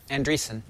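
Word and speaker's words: Andresen: Is it Andreessen or Andreessen? – Andreessen. – Andreessen, like Mark Andreessen, but not spelled the same way.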